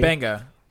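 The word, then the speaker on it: Mbenga: Yeah, it's like M B E N. – Yeah, DJ Benga. The M was like silent.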